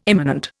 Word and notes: immanent: Indian

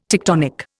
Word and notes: tectonic: Indian

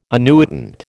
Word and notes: annuitant: American English

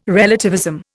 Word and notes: relativism: Indian

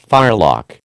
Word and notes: firelock: American English

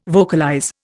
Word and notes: vocalize: Indian

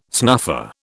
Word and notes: snuffer: British English

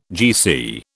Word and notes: GC: British English

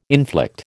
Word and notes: inflict: American English

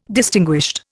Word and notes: distinguished: Indian

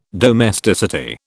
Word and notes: domesticity: British English

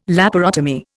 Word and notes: laparotomy: Indian

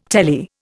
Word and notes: telly: Indian

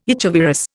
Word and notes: echovirus: Indian

